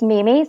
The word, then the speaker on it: Meme: So everything is my name, and it's Mimi, spelled with E's. Not eyes, and it's M-E-M-E. Everyone wants to call me meme, but I'm like, in 87, when my sister nicknamed me Mimi, internet memes were not a thing.